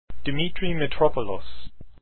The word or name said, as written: Mitropoulos